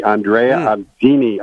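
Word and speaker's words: Andrea Atzeni: But the interesting aspect about Hackberry is not just the pedigree, Steve, but the jockey, Andrea Andini, I believe is how it's pronounced,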